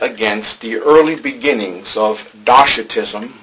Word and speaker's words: Docetism: Well, with regards to our first heresy, and hopefully I'll pronounce this right, because I have a hard time butchering this word sometimes, I believe it's pronounced Docetism.